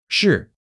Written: 是

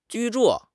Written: 居住

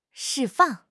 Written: 释放

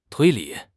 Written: 推理

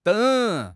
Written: deng3

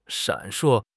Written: 闪烁

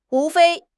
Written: 无非

